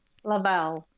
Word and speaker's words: Lavelle: Is that a short A or a long A? Lavelle. – Lavelle. Short A, I think, yeah.